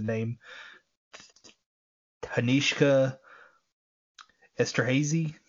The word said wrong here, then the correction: Danishka: It's like Danishka Esterhazy, and it's Danishka, then last name Esterhazy. So Danishka Esterhazy is what I probably would have went with.